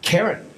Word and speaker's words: Karen: Karen? – Yes. – Rhymes with Marin.